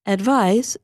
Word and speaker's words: advise: But the verb advise is written with the letter S, which is pronounced like z.